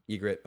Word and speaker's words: Ygritte: Was Ygritte Mance's, what's the correct pronunciation of Ygritte? – Ygritte's fine. – Ygritte, okay.